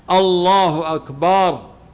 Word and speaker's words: الله أكبر: ويخطئ بعض الناس من الأئمة أو المؤذنين في النطق بهذه الجملة، فمن الأخطاء مد الهمزة الله الله، هذا يصبح استفهام، أو الله الله أكبر، بل يجب أن القطع الله أكبر، ما يقال الله أكبر، فلا بد من مراعاة ذلك.